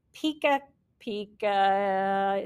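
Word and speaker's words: PechaKucha: Um, and they were using a style called Pika Pika. It sounds like Pikachu, but it is not Pikachu.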